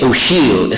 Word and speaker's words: O'Siadhail: Micheál Ó'Shiall, his name is, an Irish poet. By the way, Ó'Shiall is spelled O'Siadhail, and you'll have a handout with a poem by his before you.